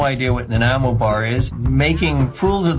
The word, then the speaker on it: Nanaimo: Listen carefully, everyone. It is Nanaimo. Nanaimo.